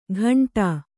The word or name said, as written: ಘಂಟಾ